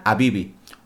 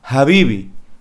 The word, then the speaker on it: Habibi: Habibi va con H. Pero realmente, si nos vamos al español, la H no se pronuncia. Habría que leerla Habibi. O si vamos al inglés, la H es más una j, Habibi, no Habibi.